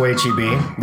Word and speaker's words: HEB: I thought it was more like Heb. – Mr. Butts. – Oh, the Heb. – It's not a long E, it's a short E.